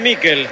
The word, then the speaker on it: Miquel: ¿Cómo le decimos? ¿Michael o Miquel? Nicolás Álvarez, usted que es especialista en nombre. Se escribe Miquel. ¿Cómo le digo? – Decile Mikel. – Entonces le digo a Mikel, usted me enseña y yo aprendo.